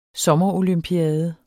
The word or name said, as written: sommerolympiade